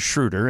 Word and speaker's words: Schroeder: I said, hey, here's a clip of Schroeder pronouncing his own name. – Right. – So you can see where I got it from. And then Schroeder says Schroeder, and then that's the beginning of it.